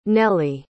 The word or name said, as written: nelley